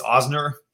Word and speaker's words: Ozner: Uh, you got it mostly right. It's not a lot of people get it. It's Ozner, but um, Ozner.